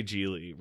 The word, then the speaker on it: Gigli: Gigli is about Larry Gigli, rhymes with really.